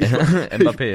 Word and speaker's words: Mbappé: Mbappé, Mbab oder wie heißt die? – Mbappé, ja.